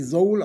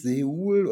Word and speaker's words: Soul: Und das wird tatsächlich Soul ausgesprochen, nicht Seoul, oder weil es wird ja S-E-O-U-L ausgesprochen.